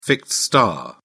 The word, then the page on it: fixed star: British English